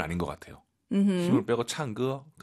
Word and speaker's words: 唱歌: 창 너무 꺼 하지 마시고 우리 선생님처럼 힘을 빼시고. – 唱歌。 – 예, 쌍기역보다는 좀 기역과 쌍기역의 느낌인 것 같아요. 가운데 느낌, 창거.